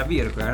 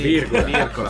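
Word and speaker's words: Wirkola: Allora, Seven Sisters di Tommy Virgola, si pronuncia Virgola, no? – Sì, Virgola.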